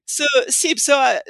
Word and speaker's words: SEIPS: So SEEPs, S-E-I-P-S, systems engineering initiative of patient safety. You know, I've heard it pronounced all kinds of different ways, SIBS and whatever. Anyway, we call it SIBS.